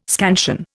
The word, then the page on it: scansion: Indian